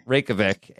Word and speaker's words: Reykjavik: So the teams headed out to Iceland to Reykjavik. Am I pronouncing that correctly? – Reykjavik.